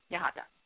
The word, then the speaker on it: Yahata: My whole name is Allison Tamie, and then Sakura Yahata.